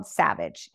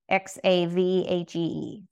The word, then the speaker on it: Xavage: Um, this first one is called Xavage. It's spelled with an X, X A V A G E, Xavage.